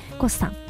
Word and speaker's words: GOSS: GOSS と書いてね、ゴスさん、そしてお寿司屋さんが 2 店舗、お魚のブリ 2門と書きまして、シモンというお店が銀座に 1 店舗、日本橋に1 店舗ございます。